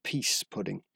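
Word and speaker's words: pease pudding: which is called peace pudding. And it's pronounced peace pudding.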